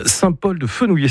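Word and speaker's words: Saint-Paul-de-Fenouillet: Saint-Paul-de-Fenouillé, c'est comme ça que vous le prononcez. – Ouais, c'est ça.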